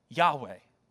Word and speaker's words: Yahweh: Which we would then transliterate and pronounce in English as we sung this morning, Yahweh, okay?